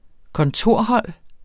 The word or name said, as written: kontorhold